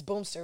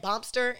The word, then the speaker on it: Boomster: It's Boomster, right? Am I saying Boomster? – Mm-hmm. – Okay, I'm saying it right. Cause I be saying Bompster, and then people be saying like, you saying it wrong.